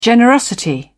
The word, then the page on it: generosity: British English